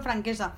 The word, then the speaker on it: Franquesa: Ahí conoció la amistad con otras mujeres grandes también como Aurora Bertrana, María Teresa Verne, Julieta Fran, Francesca, es que no sé si francesca o franquesa. Franquesa. Franquesa, claro, es valenciano.